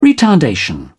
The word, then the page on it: retardation: British English